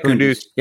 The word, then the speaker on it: Kunduz: Am I saying that right? Yep. Kunduz.